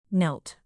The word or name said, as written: knelt